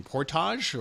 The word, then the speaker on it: Portage: You grew up in, I can't pronounce this city in Michigan, Portage, or how do you pronounce that? – Yeah, very, very, very close. Native American word, portage.